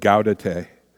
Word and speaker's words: Gaudete: There's people pronounce it different ways, but Gaudete.